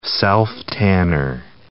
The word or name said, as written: self-tanner